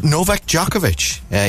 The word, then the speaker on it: Djokovic: In sport, Novak Djokovic.